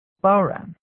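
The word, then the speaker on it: bodhrán: A bodhran, which is pronounced bowran.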